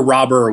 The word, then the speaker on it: Robert: Robert, I believe, is the one way you're not supposed to pronounce it, but we already declared ourselves a Luis Robert podcast, so I can't really go back. – We're a Luis Robert podcast. – He's Luis Robert. So that's it. We've declared ourselves. I can't go back on it now. – But if we were to be factual, Robert? – Or Robber without like just silent T at the end.